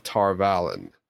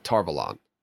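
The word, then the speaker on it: Tar Valon: But it was not until 98 AD that the city was built on the island of Tarvalen, or Tarvalon, however you prefer to say it. – Like Avalon. – Yeah, Tarvalon. I'll go back to my original pronunciation of it.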